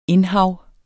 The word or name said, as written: indhav